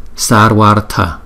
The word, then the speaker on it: Sarvartha: Rồi Sarva Artha, chúng ta đọc là Sarvartha.